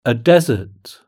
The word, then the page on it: a desert: British English